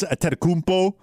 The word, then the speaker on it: Antetokounmpo: The Bucks with the Greek freak Giannis Antetokounmpo, and yes, I do believe I pronounced that correctly.